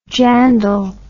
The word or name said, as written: jandal